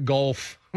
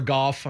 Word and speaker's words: Goff: A couple of those deep balls are ones that, that Mr. Golf or Goff, I'm not sure which one, Jared Goff.